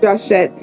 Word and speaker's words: Joshett: Yep, it's Joshett. I'm named Josh, my grandfather. His name is Josh, so he put an S on it.